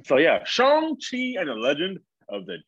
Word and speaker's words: Shang-Chi: Is it Shang or Shang? – You just said the same thing twice. – No, I didn't. I said it with a U instead of an A-U sound. – Shang. Shang. Yes, I think you put emphasis on the A in that Shang-Chi. I'm pretty sure that's how you say it.